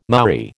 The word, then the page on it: Maori: American English